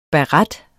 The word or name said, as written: baret